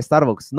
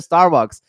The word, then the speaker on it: Starbucks: que es el Starbucks? No, se pronuncia Starbucks o sea,